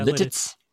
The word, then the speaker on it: Lititz: Yeah, Lititz. – Lititz, Pennsylvania. – L-I-T-I-T-Z. – That's right. I was pronouncing, I had the accent on the second half of the name, which didn't come out quite as well.